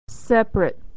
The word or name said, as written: separate